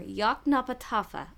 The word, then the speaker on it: Yoknapatawpha: If you break it down into syllables, it's simple Y O K N A P A T A W P H A Yoknapatawpha.